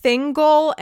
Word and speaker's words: Thingol: Chapter four is of Thingol and Melian. Are those correct pronunciations? – Correct. You even did Melian right.